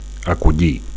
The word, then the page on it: acollir: Catalan